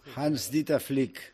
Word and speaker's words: Hans-Dieter Flick: Hans-Dieter Flick.